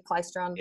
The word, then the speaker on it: Klystron: Dave's primary duties included diagnosing and repairing a variety of electronic equipment, including PC boards, Klystron, am I saying that correctly? Klystron yes, yeah.